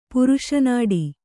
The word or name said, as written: ಪುರುಷನಾಡಿ